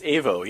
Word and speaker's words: Aevo: This new book is published by Avivo, is that how you pronounce it? – It's AVO, yeah. AVO.